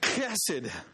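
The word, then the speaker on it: chesed (חסד): It's pronounced chesed.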